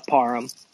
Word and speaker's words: Parham: Parham. – Parham. – Like, all right. – I say it like this, like David Beckham. It's pronounced the same way.